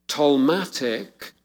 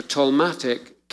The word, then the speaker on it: Ptolemaic: Okay, the Ptolemaic, it's usually a silent P, the Ptolemaic kingdom covered Egypt and Israel.